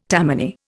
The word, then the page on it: Tammany: Indian